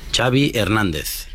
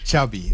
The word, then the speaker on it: Xavi Hernández: Xavi Hernández. – Xavi, okay. – Yeah. – So I heard Xavi like chocolate.